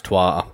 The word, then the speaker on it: Troyes: She refused to play it with me ever, and that is Trois, or Troyes, however you want to say it, but it's pronounced Trois, but it looks like Troyes.